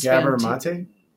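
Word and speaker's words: Gabor Máté: Like that doctor of Gamora or whatever on YouTube. Oh, he's Gabor Mate. – Is that it? I never can say his name. Gabor Mate.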